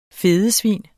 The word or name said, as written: fedesvin